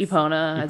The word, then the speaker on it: Epona: Epona.